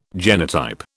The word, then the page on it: genotype: British English